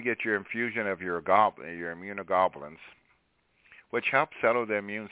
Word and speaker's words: immunoglobulins: But, Doc, can I get the goblins? What? I heard I'm right. – Can I get the goblins? – Immunoglobulins. G-L-O-B-U-L-L-I-N-S. Globulins. Globulins. – Immunoglobulins.